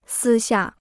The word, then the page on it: 私下: sī xià